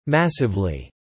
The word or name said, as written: massively